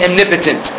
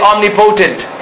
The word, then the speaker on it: omnipotent: Of course, the pronunciation is different than the spelling. It's O-M-N-I-O-M-N-I-P-O-T-E-N-T. I remember when I first learned it, I used to say omnipotent.